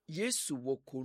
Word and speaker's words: Yesu woko: Well, how do you pronounce it? Yesu woko. Yesu woko nu mekudo.